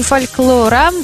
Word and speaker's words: фольклора: Всемирный день фольклора. Фольклора, да, именно так я его произношу.